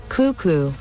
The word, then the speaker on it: cuckoo: Y la sílaba acentuada es la primera y por eso dicen cuckoo.